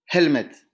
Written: helmet

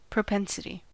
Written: propensity

